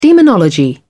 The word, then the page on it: demonology: British English